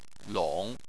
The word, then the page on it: long: 3